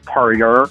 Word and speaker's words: Perrier: I think the proper pronunciation is Perrier.